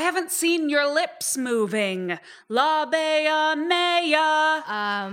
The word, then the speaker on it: Labia mea: Okay, ladies, that's close, but this is Diversionary Theater, so we've got to get our pronunciation right. Repeat after me. Labia mia. – Labia mia. – Labia mia. – Labia mea. – And now this time just Francis. Labia mea.